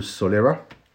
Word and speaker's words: Solera: There's only 0.5% because it's a very old Solera.